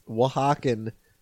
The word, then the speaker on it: Oaxaca: And they're from all over, but there's this one guy in particular who's from a place called Oaxaca. That's spelled O-A-X, a million different things. Oaxaca. And in Oaxaca, they're extremely superstitious.